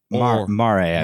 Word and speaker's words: Mare: Then you have, now, is this pronounced Mare? – Mare. – Mare. – Yeah.